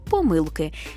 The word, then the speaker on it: помилки: Тут аж дві помилки. Маємо варіантне ще й наголошування цього слова, бо якщо йдеться про дві, три, чотири, то тоді помилки. Якщо ж більше чотирьох, наголос падає на останній склад – помилки.